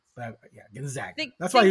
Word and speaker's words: Gonzaga: What is it, by the way? – It's Gonzaga. – Gonzaga.